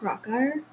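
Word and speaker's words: Krakauer: I think it's pronounced Crockeyer. – John Crockeyer, that's it.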